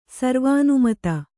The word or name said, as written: ಸರ್ವಾನುಮತ